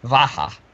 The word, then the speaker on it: Macha: So the deity whose name is spelled M-A-C-H-A is actually pronounced Vaha.